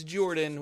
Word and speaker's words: Jorphdan: My name is Jordan, with a silent PH in the middle, and I am joined always by my wonderful co-host, Sir Lucian, over there at Sir Lucian Gaming.